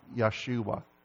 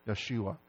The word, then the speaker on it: Yeshua: The name Joshua probably in Hebrew sounded something like Yeshua. Yeshua. They didn't have the hard J like we do. Yah. It was a Yah.